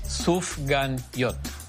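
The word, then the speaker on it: Sufganiyot: And I know it's hard to say, but it's pronounced Sufgan Yot.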